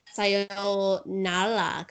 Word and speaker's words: sayonara: they pronounce their R's like kind of more like L's because they don't have an L. So it's sayonara kind of.